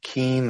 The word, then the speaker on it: Keene: Keene. That's spelled K-E-E-N-E.